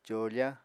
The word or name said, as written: còofa